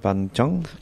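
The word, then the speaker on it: Bánh chưng: You talked about now, correct me if I'm wrong, my pronunciation is terrible, but Ban Te and Ban Chong? – You've done very well, actually. For a 5-second class, you've done really, really well.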